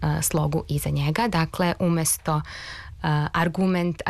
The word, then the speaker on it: argument: A ti akcenti poput argument i asistent nisu nikakvi novi akcenti, već su akcenti koji su doneti još pre dva veka u vreme Vuka Karadžića i Đure Daničića, kada je propisano da silazni akcenti mogu stajati samo na prvom slogu višesložnih reči i na jednosložnim rečima.